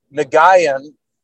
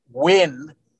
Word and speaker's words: Nguyen: I even talked to Ethan, the freshman long snapper, and it's spelled N-G-U-Y-E-N, which I was thinking Nagayan, but it's actually pronounced Wynn.